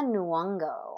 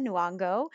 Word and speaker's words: Nyong'o: Lupita Nyong'o. – Thank you. Lupita Nyong'o, Martin Freeman, Daniel Kaluuya, Letitia Wright, Winston Duke, Angela Bassett, Forrest Whitaker, and Andy Serkis.